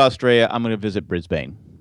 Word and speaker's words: Brisbane: Did I say that right? – Brisbane, but go ahead. – Brisbane? – Yeah. – Well, why does it say Bane? – Because it's Brisbane. Continue.